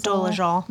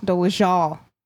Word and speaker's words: Dolezal: Dole Dolezal. – Dolezal.